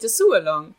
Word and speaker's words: Dessous Along: Wir sind da ja immer sehr offen, aber es braucht einen Hashtag und wir haben uns für Dessous, du kannst es viel schöner aussprechen, Dessous Sualong aus. nee, gar nicht, Dessous Along. – Dessous Along, du hast es, du hast es drauf.